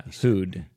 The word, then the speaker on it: who'd: So, if you're going to say it quickly, then I would use a contraction for would. – who, apostrophe D ね。 – Yeah, who'd.